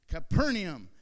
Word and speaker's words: Capernaum: Capernaum.